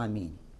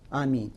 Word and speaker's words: Аминь: Многие люди ошибаются, когда произносят слово «Аминь». «Аминь» – два варианта произнесения есть. «Аминь». Либо говоришь «Аминь», «Аминь» – удлинение в начале. «Аминь». Либо говоришь «Аминь». «Аминь» и «Аминь». И так можно.